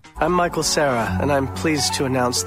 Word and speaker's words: Michael Cera: That's Michael Cera, right? – Michael Cera. – That's how you pronounce his name. Michael Cera. – Michael Cera.